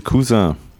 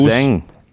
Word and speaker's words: Cousin: Gary ist äh, die österreichische Cousin. Mhm, mit Betonung auf die Kuss. Cousin. – Mit Betonung auf Kuss. Cousin.